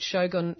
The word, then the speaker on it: Shogun: Also had really good fun looking at some fantastic tools, a little business called Shogun, is that the right pronunciation? I'm not very good with Japanese pronunciation. – Yes.